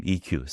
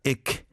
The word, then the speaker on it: EQ: For example, my name EQ. Some people, in the States used to pronounce it just as Ick. Because there's no capital Q kind of, some records would show like capital E and small letter Q, and then some would pronounce it as Ik Hwang, which is kind of not what I intended, right?